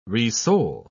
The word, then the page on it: resole: British English